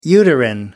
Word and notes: uterine: British English

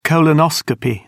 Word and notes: colonoscopy: British English

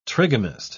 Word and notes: trigamist: British English

